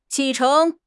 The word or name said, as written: 启程